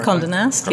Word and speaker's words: Condé Nast: Condé Nast, yes.